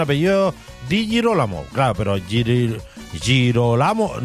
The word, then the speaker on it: Girolamo: San Jerónimo, Santa Sofía y San Girolamo. Girolamo, sí, San Girolamo. Justamente, bien pronunciado, bandita, bien.